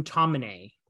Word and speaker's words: Tomine: Today's book is The Loneliness of the Long Distance Cartoonist by Adrian Tomine. Very certain I've pronounced his name correctly, unlike most of the people in this book.